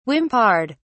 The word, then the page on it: whimpered: British English